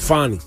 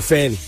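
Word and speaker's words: Fani: The way she pronounces it, Fonnie. But most people just call her Fanny.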